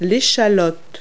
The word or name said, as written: shallot